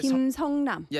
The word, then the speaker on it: 김성남: How do I say that? 성남. – Yeah, 김성남. – Yes. – Because it's a K and a net.